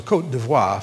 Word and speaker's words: Côte d'Ivoire: We have the Tim and Barbara Downs in the Ivory Coast, or Côte d'Ivoire, if you want to say it right.